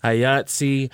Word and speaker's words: IATSE: Is currently in, is that IASTE? – Mm-hmm. – How do you say that? – IATSE, Local 706, as a union hairstylist, colorist, and barber.